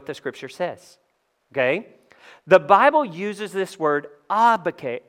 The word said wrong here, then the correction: agape: Uh, I'm sorry. See, it messes me up to pronounce it correctly. It's so crazy. Agape, agape, okay? Agape.